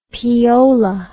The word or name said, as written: peola